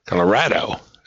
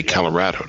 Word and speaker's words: Colorado: Colorado. Is that how you say it? I think you say Colorado, don't you? – Yeah. How do they say it in Texas? Colorado.